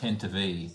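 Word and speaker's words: Tantive: The Tantivore, as seen at the start. Yes, and you get a point as well, for knowing what it's called. – Well, that's pronounced Tantivy.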